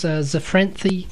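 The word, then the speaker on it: Zephyranthes: So the botanical name is Zafranthi candida. So if you've got a lisp, then it's very easy to pronounce. Zafranthi.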